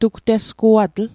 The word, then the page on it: tugtesguatl: Mi'gmaq